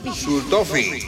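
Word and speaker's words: Xultophy: La Sultopi, insulina Sultopi. – Sultofi. – Sultofi, eso, Sultofi. Usted que es prácticamente alemán, pronuncia mejor que yo.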